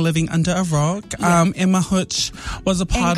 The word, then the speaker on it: Hutch: You know who I'm referring to, H-U-T-C-H. Is that how you Hutch? – Yes.